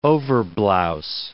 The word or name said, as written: overblouse